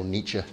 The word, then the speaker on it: Nietzsche: Um, the, famous uh, German philosopher Friedrich Nietzsche once said, or Nietzsche, depending on which language you're pronouncing him in, um, once said, Why should you pay attention to truth?